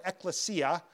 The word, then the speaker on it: ecclesia: The church, this word ecclesia, and it's pronounced different ways, I think you'll hear it different ways, but that's the way I'll say it,